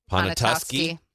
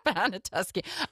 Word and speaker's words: Poniatowski: And he says, I believe it's pronounced Ponatusky. Ponatusky. Yeah, sorry. – We're not natives, Jeffrey. – Depends on if you're from there or not. Ponatusky. I know that because my dad corrected me yesterday, and I went back to my old ways.